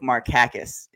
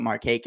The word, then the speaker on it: Markakis: I'm pretty certain that it's actually pronounced Nick Markakis in the Greek. And Nick, being a quiet guy, never bothered to correct anybody. So we always called him Markakis, and he was like, whatever.